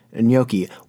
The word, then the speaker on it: gnocchi: or it's not well known that it's actually pronounced gnocchi, which I wouldn't be surprised.